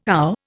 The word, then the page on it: ow: Indian